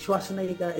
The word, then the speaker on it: Schwarzenegger: Schwarzenegger is Austrian. And so can you find it anywhere? And so you cannot anglicize that name. What would you do? You stick to the pronunciation.